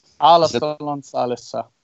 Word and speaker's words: Alastalon salissa: It's Walter Kilpie's Alastalon Salissa. Is that how you pronounce it? – Alastalon Salissa.